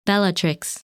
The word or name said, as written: Bellatrix